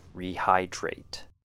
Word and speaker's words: rehydrate: 3 syllables. Re has a long E sound. High sounds like the word high, as in high or low, and drate. DR sound combined with the word eight, rehydrate.